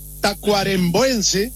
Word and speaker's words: tacuarembuense: ¿Cómo está la cuestión económica, empresario líder de acá de Rivera, tacuarembuense, se dice así? – Sí, señor.